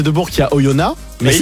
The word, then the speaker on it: Oyonnax: Ça s'écrit avec un X à la fin. Si tu vas là-bas et tu dis Oyonnax. – Ah oui, mais ça c'est logique. – Tu vas voir ce qui va t'arriver.